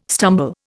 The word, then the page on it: stumble: Indian